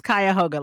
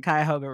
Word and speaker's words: Cuyahoga: It's pronounced Cuyahoga, like the Cuyahoga River.